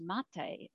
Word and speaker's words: mate: But it also is pronounced mate in Māori, and it means death.